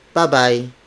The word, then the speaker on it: babay: The Filipino word would be babay, bye bye, babay.